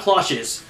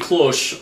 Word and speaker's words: Cloche: Well, if you noticed when they actually, put the title, when they put Cloche, and it's Cloche, not Cloches, because this is French. You don't pronounce, the last letter, but you do pronounce the last letter as Cité because it has the accent on the E.